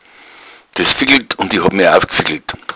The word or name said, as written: fickeln